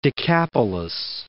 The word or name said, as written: Decapolis